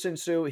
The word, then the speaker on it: Shinsu: Yeah, Shinsu. – Shinsu. I didn't want to pronounce it wrong. So it's Shinsu.